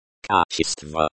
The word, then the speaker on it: Качество: Качество. Качество. This is the noun, quality. The stress is on the second syllable, ч.